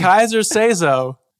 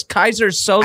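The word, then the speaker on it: Keyser Söze: It's Kaiser say so. It is Kaiser say so. – No, it's Kaiser Sose.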